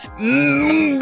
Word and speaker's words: Gohan: Just a little slower and more emphasis on the G, please. – Sure. Gohan.